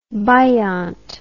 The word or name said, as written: biont